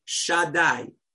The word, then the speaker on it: Shaddai: The name that's written, that God's name, is called Shaddai.